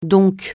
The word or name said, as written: donc